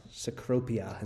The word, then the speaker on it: Cecropia: He says, one of the many fascinating events in nature is the emergence of, and I think it's it's supposed to be the S, Cecropia is how you pronounce that, Cecropia moth, from its cocoon, an event that occurs only with much struggle on the part of the moth to free itself.